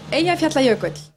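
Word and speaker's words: Eyjafjallajökull: Vous pouvez nous dire comment on prononce le nom du volcan ici Ça se prononce Eyjaflatlajökull.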